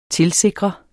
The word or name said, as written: TILSIKRES